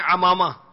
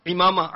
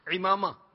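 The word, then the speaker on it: عمامہ: امامہ، بعض کہتے ہیں امامہ، امامہ نہیں، امامہ، عین کے نیچے زیر ہے. صحیح تلفظ کیا ہے؟ امامہ.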